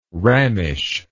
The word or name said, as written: rammish